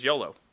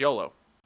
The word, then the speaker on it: Iolo: No. It's I-O-L-O. – ELO. – No, it's YOLO. – ELO. – No, it's YOLO. – I O-L-O. We have our own language, and we have different enunciations, and it is...